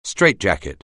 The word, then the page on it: straitjacket: anglais